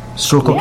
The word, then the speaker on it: Schuylkill: Is that how you pronounce it, Schuylkill? – Schuylkill, yeah.